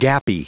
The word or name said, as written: gappy